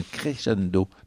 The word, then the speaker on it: crescendo: Mais le crescendo, la belle, la définition de Grégory Charles d'un crescendo.